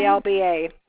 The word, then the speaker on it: Melba: MELBA.